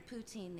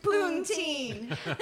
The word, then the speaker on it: poutine: All right, so now the Dive, you have, I tried your poutine the other day.